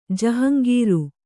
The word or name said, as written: ಜಹಂಗೀರು